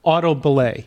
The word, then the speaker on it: auto belay: What's a auto belay? – Yeah.